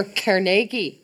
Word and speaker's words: Carnegie: Or Carnegie. – Carnegie. Gotta say it right. Say it with a Scottish accent.